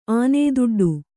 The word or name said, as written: ಆನೇದುಡ್ಡು